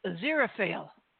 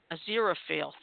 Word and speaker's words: Aziraphale: Surely, that name you couldn't pronounce is Aziraphale. – Aziraphale, thank you.